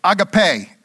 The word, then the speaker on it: agape: It's the Greek word agape, or agape, as it may be pronounced in the Greek.